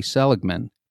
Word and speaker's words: Seligman: Marty Seligman. How did I do, Renee? – You did amazing. You said his name right.